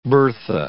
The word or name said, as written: Bertha